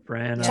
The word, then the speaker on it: Branagh: Kenneth Branagh. – That's how you say it? – Branagh.